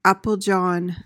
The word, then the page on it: applejohn: AP-uhl-jon